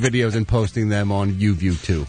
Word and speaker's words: YUVUTU: I think what's it's YUVUTU. – Okay. – It's spelt that way, which is kind of funky, but I believe they call it UView 2.